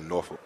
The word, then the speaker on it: Norfolk: Does everybody mispronounce Norfolk, Virginia? – Yes, they do. It's called Norfolk. Like, you don't – everybody. – Sounds like you're cussing, right? – Yeah, basically. Yeah.